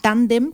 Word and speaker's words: Tándem: Tandem con acento en la primera A y Cia de Danza, así los encuentran.